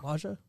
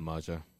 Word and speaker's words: Maajja: We introduced the show Maajja. Am I saying that right? – I don't know. – Maajja. Go with Maajja.